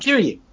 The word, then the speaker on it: Kiryu: Kiryu.